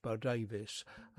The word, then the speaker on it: Bodawes: So the name to remember, Janet Bow Davis, and the Janet is spelt with two N's, and Bow Davis is actually spelt Bodawes, but it's pronounced Bow Davis, and a very interesting artist who's done very well for herself over in the Netherlands.